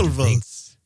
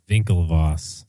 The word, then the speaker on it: Winklevoss: Oh, see, I was saying it with the German W pronunciation. – Winklevoss. – Winklevoss.